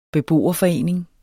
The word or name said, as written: beboerforening